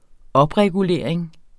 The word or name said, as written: opregulering